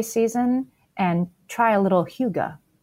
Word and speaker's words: hygge: That would be very hygge. It's a hygge. I didn't know how to pronounce it until I looked it up for you guys. It's that word you sometimes see on Pinterest, H-Y-G-G-E.